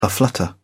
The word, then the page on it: aflutter: British English